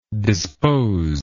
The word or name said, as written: disposingly